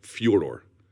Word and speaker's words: Fyodor: My understanding is the proper pronunciation is something more along the lines of Fyodor, like a F-Y-O-D-O-R, rather than Fi or Fe, Fyodor.